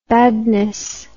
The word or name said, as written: badness